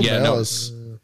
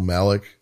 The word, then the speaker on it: Malice: But Michael Malice. – It's funny that they brought him up, right? In this, and they said his name wrong, Michael Malik.